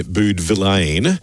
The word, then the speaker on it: Boudevillain: I've been told by a couple of people that it's actually, apparently, it's a silent N. If you look at his name, it says Pierre Boudevillain, but apparently it's pronounced Boudevilla.